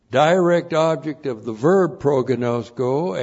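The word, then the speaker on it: pro-gnosko (προγινώσκω): Looks like that in the Greek, that's P-R-O-G-I-N, long O, S-K, long O, pro-gnosko.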